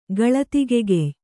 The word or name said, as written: ಗಳತಿಗೆಗೆಯ್